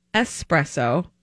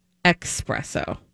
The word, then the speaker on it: espresso: Number 1, the word is pronounced espresso, not expresso.